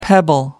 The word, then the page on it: pebble: American English